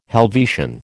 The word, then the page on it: Helvetian: American English